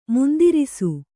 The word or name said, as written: ಮುಂದಿರಿಸು